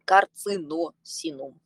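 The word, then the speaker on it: Carcinosinum: Как по-латински пишется карсиносинум? Карсиносинум. Первые две – это Си, последнее это С. Карциносинум, по факту.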